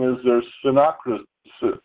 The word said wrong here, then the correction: synchronicity: I'm mispronouncing it. Synocrisis. – Synopsis? This is a synopsis of what synchronicity.